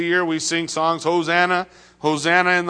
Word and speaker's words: Hosanna: How do you say that in Hebrew? Hosanna.